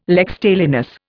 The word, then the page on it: lex talionis: Indian